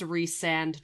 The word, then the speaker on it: Rhysand: It's pronounced Rhysand, just so you know. – Ugh, Okay. – I know it's dumb. It's dumb, but it's resand.